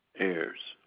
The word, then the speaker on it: Ayres: 8-3-1. Correct. Right. And whom I have the pleasure of speaking with today? – Arthur Ayers. – And their last name is spelled A-I-R-S? – No, A-Y-R-E-S. – A-Y-R-E-S? – Correct.